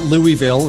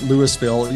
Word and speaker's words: Louisville: It's not Louisville. You have to say it Louisville. – Right? Louisville. – Okay. – Louisville. – Louisville. Yeah. All right. It's just like it's not New Orleans. It's New Orleans, right? – New Orleans, right. Right. And we have a t-shirt here that has all the different pronunciations of how to say, of how people say it, but it is Louisville.